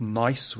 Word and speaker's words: gneiss: We call them metamorphic rocks, and they have lovely names like schist, say it carefully, and gneiss with a silent G.